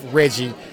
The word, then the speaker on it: Reggie: My name is William Sloan, but they call me Chef Reggie. That rhymes with veggie.